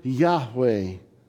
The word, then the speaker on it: Yahweh: It's YHWH. The best way we can pronounce it is kind of Yahweh.